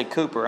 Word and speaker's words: Cowper: William Cowper said it. And I say Cooper, I'm sorry, that's really the right pronunciation.